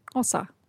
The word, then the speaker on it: Xhosa: It's actually spelled X-H-O-S-A, and the X is usually pronounced with a click. So it should be Xhosa.